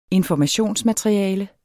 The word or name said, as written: informationsmateriale